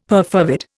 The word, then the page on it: perfervid: Indian